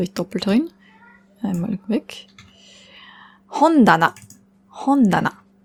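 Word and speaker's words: Hondana (本棚): Hon ist das Buch, Regal heißt Tana. Aber nur wenn es alleine steht, dann spricht man es ta aus. Steht es allein, steht es zusammen in Kombination mit dem hon, dann ändert sich die Aussprache auf da. Hondana.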